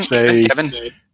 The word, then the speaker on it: Esri: No, it's Esri because it's the same as the name of the Trill symbiont who gets forcibly implanted with the symbiont against her will.